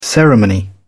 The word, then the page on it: ceremony: British English